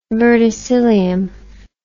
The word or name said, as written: verticillium